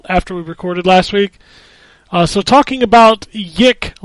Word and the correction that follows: YIIK: last week, I'm pretty sure it's pronounced Y2K.